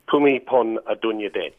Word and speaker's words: Bhumibol Adulyadej: Bhumibol Adulyadej. – Bhumibol Adulyadej.